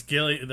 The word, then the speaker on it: Gillian: It's Gillian. The one from Community is Gillian, but she's spelled the same way.